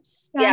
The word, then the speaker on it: Steiner: bad uh, it's, it's, Kandi Steiner. Steiner yes, you yeah. said it right.